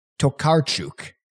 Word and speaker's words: Tokarczuk: I looked up the phonetic pronunciation. Tokarczuk, Polish author and winner of the Nobel Prize in 2018.